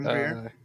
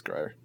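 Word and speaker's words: Grier: Pam Greer. – Greer, is that how you say it? – Yeah. – That's Greer.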